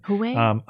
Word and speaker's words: Huawei: Huawei hires Democratic lobbyist Tony Podesta.